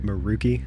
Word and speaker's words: Maroochy: Do you think I'm saying that right? Maroochy? – Yeah, actually, it's probably pretty close.